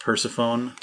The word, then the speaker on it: Persephone: It's pronounced Persephone.